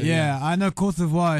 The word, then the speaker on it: Côte d'Ivoire: Cote d'Ivoire is how you say it. Yeah, in French.